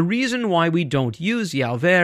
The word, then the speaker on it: Yahweh: This is sometimes written out as Yahweh or Yahweh. This is where we get the mispronounced name Jehovah.